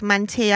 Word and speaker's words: mantilla: So, yeah, the hair of Florencia, again, made that they're sort of like mantilla out of the plaits. – Notice the correct pronunciation there. Mantilla. – Some of us did Espanol GCSE.